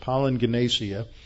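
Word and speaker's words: palingenesia (παλιγγενεσία): Now, when you see a double G in Greek, that's usually pronounced like an NG, so I pronounce it palingenesia.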